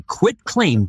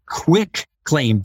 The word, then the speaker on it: quitclaim: And another little horror story is if you use a quitclaim deed, which people mispronounce as quick claim deed, like yes.